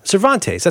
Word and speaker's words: Cervantes: Cervantes, that's how you pronounce it, right? – Sure. I mean, that is the correct pronunciation.